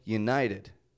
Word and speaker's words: United: Give these words a listen. United States. Do you hear it in there? I'm not saying United States, but United. United States.